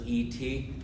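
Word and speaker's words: et (את): I've seen some translations say it's spelled E-S, others E-T. It's pronounced et.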